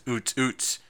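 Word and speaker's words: Ootz: Tuesday, artist of the week, that is Oots Oots. It's spelled 00TZ, 00TZ, and it sounds just like, well, you know, like the beat does.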